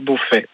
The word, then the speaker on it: Beaufey: Sinon, c'est à mon nom, Jean-Pierre Beaufait. – Ah, Beaufait. Ouais. Alors ça s'écrit comment, Beaufait ? – B-E-A-U-F-E-Y. – B-E-A-U-F-E-Y, Jean-Pierre Beaufait à Uzès.